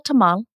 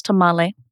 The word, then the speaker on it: tamal: So I think of a tamal, which many of us in English pronounce tamale,